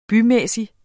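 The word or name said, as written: BYMÆSSIG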